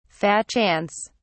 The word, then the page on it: fat chance: British English